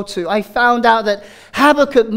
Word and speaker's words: Habakkuk: but it's Habakkuk.